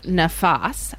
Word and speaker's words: nafas: And the first question we had for you is, can you tell us more about the concept of nafas? I'm not sure if I pronounced that right. – Yeah, you did well.